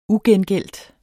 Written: ugengældt

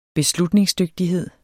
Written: beslutningsdygtighed

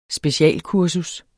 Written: specialkursus

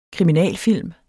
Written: kriminalfilm

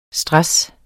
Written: STRESSEN

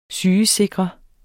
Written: sygesikre

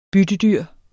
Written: byttedyr

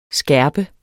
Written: skærpe

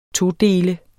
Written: todele

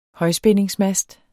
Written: højspændingsmast

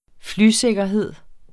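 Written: flysikkerhed

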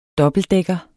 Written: dobbeltdækker

